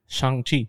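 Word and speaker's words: Shang-Chi: Shang Chi.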